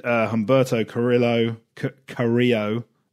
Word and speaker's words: Humberto Carrillo: Drew Gulak defeated Humberto Carrillo, is how it's pronounced, and Lince Dorado.